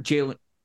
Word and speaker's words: Jalen: Mel's got Jalen.